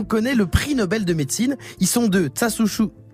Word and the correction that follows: Tasuku: c'est ah Tasuku Onjo et James Allison pour leur traitement des tumeurs cancéreuses par inhibition de la régulation immunitaire négative.